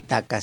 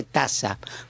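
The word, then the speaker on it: taça: No se dice taca, se dice taza. Porque es la C con el ganchito abajo.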